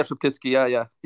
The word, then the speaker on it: Sheptytsky: Yeah, Sheptytsky.